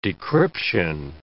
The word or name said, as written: decryption